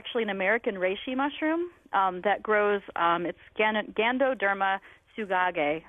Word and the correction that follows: tsugae: Gandoderma, and then the second word is Tsugae, is the scientific name.